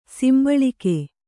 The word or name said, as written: ಸಂಭಾಳಿಕೆ